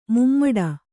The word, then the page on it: ಮೂಮಡಿ: kannada